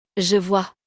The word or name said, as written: je vois